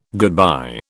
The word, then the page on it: goodbye: British English